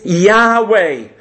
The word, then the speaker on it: Yahweh: A better pronunciation of it is Yahweh.